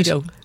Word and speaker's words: Reido: It's Helen, H-E-L-E-N-O, Riedo. Am I saying that right? – Riedo, R-E-I-D-O.